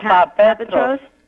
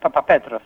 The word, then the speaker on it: Papapetros: Papapetros. – Papapetros. – Papapetros. – That's it, Papapetros, yes.